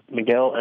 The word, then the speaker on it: Miguel: Miguel, M-I-G-U-E-L. – Okay. – Oliveira. O-L-I-V-E-R-O. – V-E-R-O? Okay. Hi, Miguel. My name's Erica. V-E-R-O.